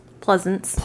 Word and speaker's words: Pleasence: Donald Plessen recounted, his last name made me think of Snake Plissken. – P-L-E-A, I'm probably saying it wrong, but P-L-E-A-S-E-N-C-E. – Pleasance. – Pleasance. Snake Pleasance.